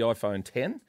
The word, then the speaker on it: iPhone X: So it's called the iPhone X, but it's actually 10. You pronounce it the iPhone 10.